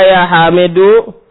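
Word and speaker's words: Ya Hamidu (حَمِيدُ): Kalau nama orang, ada ya, dibacanya. didomahkan. Dan sengaja Anda dibaca semua harokatnya ketika latihan baca, walaupun di akhir, supaya selalu ingat, oh berarti harokatnya ini, kalau ketemu ini, harokatnya ini. Jadi baca ya hamidu.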